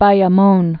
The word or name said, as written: Bayamon